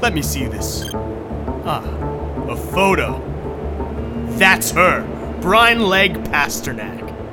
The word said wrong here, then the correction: Brynleigh: I think it's pronounced Bryn Lee.